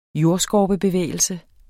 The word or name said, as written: jordskorpebevægelse